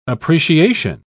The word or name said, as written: appreciation